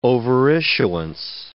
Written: overissuance

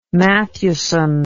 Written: Mathewson

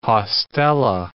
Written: haustella

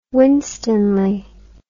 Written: winstanley

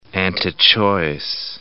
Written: antichoice

